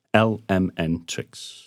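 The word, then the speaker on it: LMNTRIX: It's spelt L-M-N and then T-R-I-X. So some people call it Lemontrix. Some people call it Lemontrix. Some people don't know what to call it and just ask, how do you pronounce it? It's L-M-N tricks.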